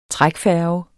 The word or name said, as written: trækfærge